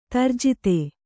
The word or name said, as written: ದುರ್ಜಾತ